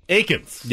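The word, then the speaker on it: Eakins: Aikens.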